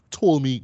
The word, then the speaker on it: Ptolemy: Which is called The Last Days of Ptolemy Grey, right? The P is silent, right? Similar to, you know, Django, you know, the D is silent, right?